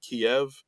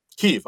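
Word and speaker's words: Kyiv: We see that there are some candles in front of them that spell out Kiev. – And Kiev. – I'm sorry. – That's the Ukrainian pronunciation. It's the one syllable pronunciation. Kiev is apparently the Russian pronunciation.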